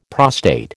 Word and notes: prostate: American English

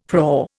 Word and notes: prau: Indian

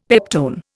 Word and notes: peptone: Indian

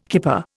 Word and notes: kipper: Indian

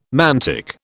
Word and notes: mantic: British English

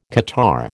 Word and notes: catarrh: American English

